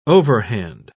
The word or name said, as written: overhand